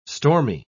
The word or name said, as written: stormy